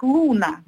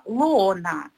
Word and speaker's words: Лоона: Кстати, ее второе имя часто произносят как Луна на английский манер. Но нет, она именно Лоона с 2 О.